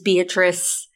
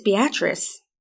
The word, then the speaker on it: Beatrice: You mean Beatrice? – No. I watched the docu-series, and I've been pronouncing it as Beatrice. It's Beatrice. At least that's what they said.